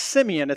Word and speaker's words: Simeon: It's actually pronounced Simeon. It's another version of the name Simon, which was his original name, and then the name Jesus gave him was Peter.